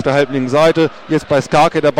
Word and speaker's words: Skarke: Schnatterer geht raus, und es kommt Tim Starke mit der Nummer 38 für Marc Schnatterer, der ein ganz, ganz starkes Spiel gemacht hat, viele Offensivakzente gesetzt hat. Und Skarke, Entschuldigung, nicht Starke, sondern Skarke, ich falls ausgesprochen,